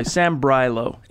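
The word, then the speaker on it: Sambrailo: Even if Sambrylo, am I saying that right? – That a boy, Sambrylo.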